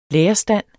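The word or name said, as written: lærerstandens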